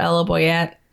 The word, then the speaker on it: Ella Boyett: Also on Byte, it's all under the same name, at Ella Boyette.